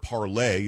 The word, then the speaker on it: Parler: It is, the word is not Parler, P-A-R-L-O-R, it's P-A-R-L-E-R, which is French for to speak. The word parlay is spelled P-A-R-L-E-R. When you send something on Parler, it's not a tweet, it's not a post, it is a parlay.